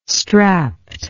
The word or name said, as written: strapped